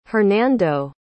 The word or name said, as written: Hernando